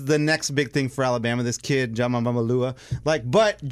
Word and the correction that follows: Tagovailoa: Get the pronunciation down what is it? Next week. I don't know. – Tua Tagovailoa. No, Tua. – Tua Tagovailoa.